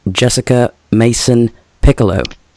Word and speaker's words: Jessica Mason Pieklo: Please welcome Jessica Mason Pieklo.